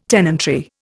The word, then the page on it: tenantry: Indian